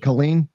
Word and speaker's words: Killeen: No, but Killeen, Texas, they've got a reputation for having weird stuff going at Killeen. There's all kinds of. – Is that how you pronounce it? Killeen? – Killeen. – Yes, yeah.